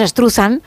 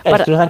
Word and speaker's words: Struzan: como cada uno lo quiera pronunciar, nosotros decimos Struzan. – Struzan, que es más fácil.